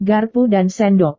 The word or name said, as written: garpu dan sendok